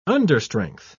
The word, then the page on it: understrength: British English